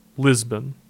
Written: Lisbon

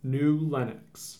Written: New Lenox

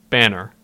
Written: Banner

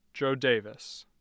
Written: jo daviess

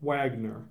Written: Waggoner

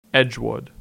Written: Edgewood

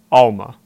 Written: Alma

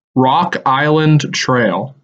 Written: Rock Island Trail

